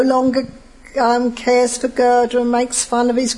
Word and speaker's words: Gerda: Gerda and Kai.